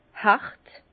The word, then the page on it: hert: Bas-Rhin; Herrlisheim